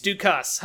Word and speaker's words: Dukas: Oh, it says it's French. Like, it's French pronunciation. – Oh, it is. It's Dukas. Ha.